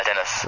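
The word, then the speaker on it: Deniz: Dennis wird das nicht, mit Z wird ja nicht hart ausgesprochen, ne? – Nee, ganz normal, Dennis.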